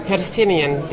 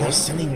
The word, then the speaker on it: Hercynian: What, how do you pronounce it? Hercynian Distilling. Hercynian, right. Okay, take us through um, the distillery and what's on the table.